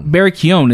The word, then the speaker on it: Keoghan: Barry Keoghan is the pronunciation of the name, right? – B. Keoghan. – We've been calling him Barry Keoghan. – I'll call him Barry Bees. – Barry Bees. Yeah. – G is silent.